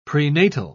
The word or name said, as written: prenatal